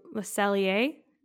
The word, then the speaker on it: Le Cellier: I thought about it a lot and compared to Le Cellier. I'm saying it wrong again. Thank you. – No, you said it right.